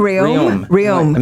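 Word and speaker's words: Rheaume: Do you know how to say their last name? Is it Ruem? – Ruem. Amanda Ruem.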